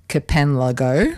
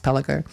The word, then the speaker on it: Kpalango: So, yeah, so it's spelled actually K-P-A-L-A-N-G-O, which you might think sounds like Kapanlago, right? – Yeah, it's like Pialago down in, in Cambros. – But being a Ghanaian word in the language of Ga, which is the language it's in, it's pronounced Pelago.